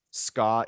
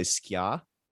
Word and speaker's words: ska: Also, I'm married to a Jamaican woman, and I know that ska is also an Americanized pronunciation. For many Jamaicans, they would say skia.